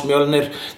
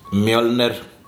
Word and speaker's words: Mjölnir: And this is how we pronounce Mjölnir, dear American and all around foreign Marvel nerds. Mjölnir is Thor's hammer, and it is called so because it crushes things with such power that they turn into dust.